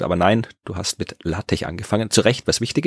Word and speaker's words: LaTeX: Also LaTeX geschrieben, aber LaTeX ausgesprochen.